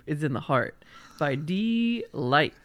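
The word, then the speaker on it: Deee-Lite: And that's how you pronounce that, by the way. – It's yeah, D-Light. It is. That's right. It's D-Light. There's multiple E's in there. There's no doubt.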